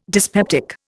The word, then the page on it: dyspeptic: Indian